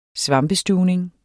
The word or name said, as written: svampestuvning